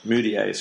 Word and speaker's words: Mudiay: I mean, the minimal you can look to move later on, but if they bring in Emmanuel Mudiay, did I say that right? – Mudiay, sorry.